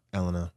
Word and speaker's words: Elena: Her name was Elena, by the way. – Elena? What did I say? Elsna? – You said Ellen. – Yeah. Oh, it's like they were pronouncing it like Elena. – Elena. – Yeah. Elena, yeah. E.